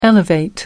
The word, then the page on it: elevate: American English